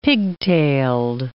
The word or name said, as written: pigtailed